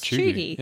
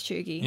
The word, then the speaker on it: cheugy: And the code word for this week is Chuggy. – Yeah, perfect. – To clarify, it's spelt Cheugy.